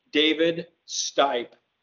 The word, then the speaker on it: Stipe: David Stipe.